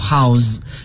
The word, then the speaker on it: house: 其实名词也是这个 house，对，但是做名词时它的发音 house，可是作为动词变作动词之后呢要读作 house。